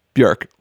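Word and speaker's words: Björk: That's what she says. Bjork. Rhymes with jerk.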